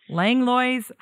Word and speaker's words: Langlois: Langlois.